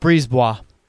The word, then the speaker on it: Brisebois: But Julien Brisebois, the general manager, it is Brisebois, right? That's how you pronounce it? – Brisebois.